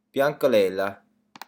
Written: biancolella

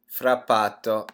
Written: frappato

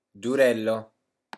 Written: durello

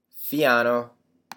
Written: fiano

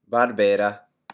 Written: barbera

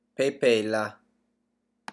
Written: pepella

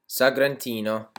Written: sagrantino

